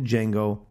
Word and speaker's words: Django: 1 is an iTunes bonus track called Ode to Django, the D is silent by the RZA.